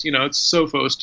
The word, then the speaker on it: Sophos: Yes, being of Greek descent, I pronounce the O's long, so it's Sophos to me.